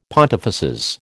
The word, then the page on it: pontifices: American English